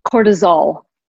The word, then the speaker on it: cortisol: I was actually doing some research last night on cortisone. How do you say it? Cortisone? – Cortisol.